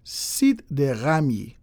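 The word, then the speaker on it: Site des Ramiers: Site de Ramiers.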